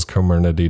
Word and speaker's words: Comirnaty: Comirnaty. It's spelled Comirnaty, Comirnaty, but it's Comirnaty.